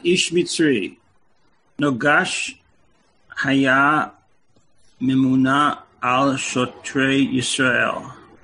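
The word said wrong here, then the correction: noges (נֹגֵשׂ): So the word is pronounced noges. It's a sin, not a shin.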